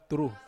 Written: turu

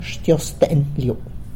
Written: Xtyozën liu

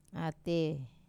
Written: ate

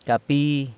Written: capi

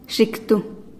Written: xiktu

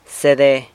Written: sede

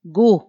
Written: gu

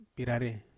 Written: parare

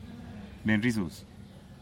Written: benrizuz

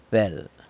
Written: bel

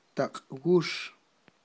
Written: taqwux

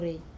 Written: rei

